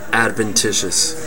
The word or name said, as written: adventitious